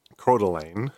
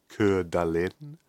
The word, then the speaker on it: Coeur d'Alene: Coeur d'Alene, according to local pronunciation, I would pronounce it Coeur d'Alene. I think my French pronunciation is slightly better than my American pronunciation there.